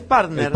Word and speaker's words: partner: Pero en inglés es partner.